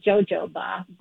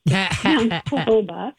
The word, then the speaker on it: jojoba: But our jojoba oil, which is spelled J-O-J-O-B, looks like jojoba, but jojoba.